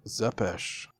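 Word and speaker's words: Țepeș: But it says, remember, it's pronounced Zepesh.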